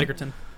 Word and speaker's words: Edgerton: I can never say his name right. – Edgerton. – Edgerton.